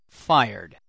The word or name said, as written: fired